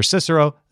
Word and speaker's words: Cicero: The accepted pronunciations, though, are to do the C's as S's. That's why you hear Cicero. That's why you hear Circe.